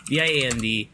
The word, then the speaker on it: viand: V i a n d.